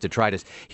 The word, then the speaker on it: detritus: No, I'm almost positive that it's pronounced detritus.